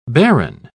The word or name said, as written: baron